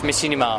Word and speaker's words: Machinima: Do you or any of the other Rooster Teeth guys work on anything outside Makimana? Actually, am I saying it? How do you say Makimana? That's Machinima.